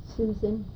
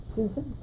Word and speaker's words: Susan: Susan DePiggin. – Susan, S-U-S-A-N. – D-E-P-I-G-I-A-N. – Richard, R-I-C-H-A-R-D, DePiggin, D-E-P-I-G-I-A-N.